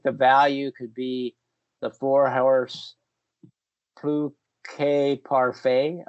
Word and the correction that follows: Plus Que Parfait: Plus Que Parfait. – Plus. So you do pronounce the S, huh? – Plus.